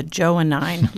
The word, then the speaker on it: Johannine: And Bishop, as you just said to me before we started, this is a Johannine letter.